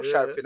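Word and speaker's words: Sharpe: Eu vi o programa lá com o Marshall Lynch conversando lá com, com o Sharp. – É o é, Sharp, né? Agora eu falei certo.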